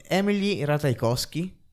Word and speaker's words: Emily Ratajkowski: David Fincher ingaggiò Emily Ratajkowski,